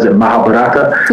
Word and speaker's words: Mahabharata: Mahabharata.